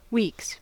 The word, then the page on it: weeks: American English